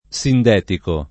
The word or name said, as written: sindetico